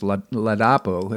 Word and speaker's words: Ladapo: The events have become so overwhelming that, as of this week, Florida Surgeon General I say his name incorrectly. I'm going to try to say it correctly. Lapado I think it's Ladapo, that's what it is,